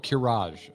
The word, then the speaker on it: Qiraji: How do you say that word? Q-I-R-A-J-I. The Quirigi. – All right, start from the top again. – The Night Elves and the Dragonflight stood against the armies of the Quirigi in what war? – Karaji? – Is it Karaji? Maybe. There's no other I in there. All right, in what war? – Oh, Kiraj. Okay. – Is that Kiraj? Like Ankaraj? – Yes.